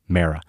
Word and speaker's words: Mara: And they never refer to Wellington Mara, the patriarch, as Wellington Mara. Never heard that. It's always Wellington Mara.